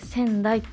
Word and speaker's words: 川内: 仙台」って読むんですけれどもこちらで開催されましたカーボンニュートラルフェア in 薩摩仙台